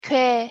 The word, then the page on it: 궤: Korean